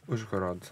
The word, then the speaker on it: Uzhhorod: Is Uzhhorod? – Uzhhorod.